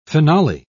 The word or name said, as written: finale